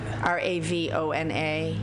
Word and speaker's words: Ravona: R A V O N A.